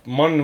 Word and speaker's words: Munn: näitleja, kes mängib Predatorites, kelle nimi on Olivia Munn või Munn, ma ei tea, kuidas seda täpselt hääldatakse. – ei ole vahet, sa hääldasid juba õigesti.